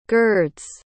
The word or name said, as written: girds